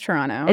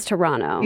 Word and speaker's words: Toronto: I was told that you are not supposed to pronounce the second T in Toronto. Is that true? – That's true. It's Toronto. – It's Toronto.